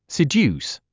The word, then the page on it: seduce: sid j úːs; スィデュース